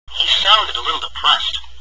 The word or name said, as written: sounded